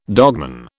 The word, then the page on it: dogman: British English